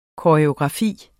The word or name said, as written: koreografi